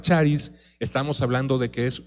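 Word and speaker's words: charis (χάρις): Y algo muy interesante es cómo la palabra don en el Nuevo Testamento está relacionada con una palabra griega que se llama, o que se pronuncia, charis.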